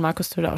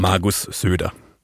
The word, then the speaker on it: Söder: Kannst du nochmal richtig schön Markus Söder aussprechen? Bitte? Markus Söder. – Ja, genau so nehme ich.